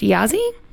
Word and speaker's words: Yasi: So anyway, her name is, I believe it's pronounced Yasi. It's Y-A-S-I, and if I'm messing that up, I'm really sorry.